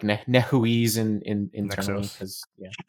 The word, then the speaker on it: Nexuiz: Nexus. It's spelled weird. It's like N-E-X-U-I-Z.